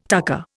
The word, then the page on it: tucker: Indian